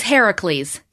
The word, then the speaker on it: Heracles: Yeah, he's Heracles in Greek. – And thank you for pronouncing that properly.